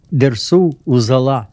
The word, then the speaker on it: Dersu Uzala: In Russian, it is pronunciation is close to probably to original one. Dersu Uzala.